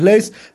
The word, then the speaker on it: Leys: Mi apellido se escribe LEYS. No aclaro porque a veces no saben cómo escribirlo. Mi nombre es Lucas Leis, así que ahí estoy en Twitter, en Facebook también.